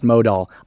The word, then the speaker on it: modal: Oh, right here it says pronounced modal. I nailed it.